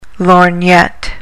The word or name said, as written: lorgnette